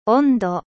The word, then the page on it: température: French